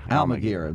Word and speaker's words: Almaguer: Well, MSNBC's national correspondent Miguel Alguerez. – Alguerez, right. Really, it's not spelled that way. It's spelled Almaguer.